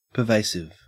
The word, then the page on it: pervasive: Australian English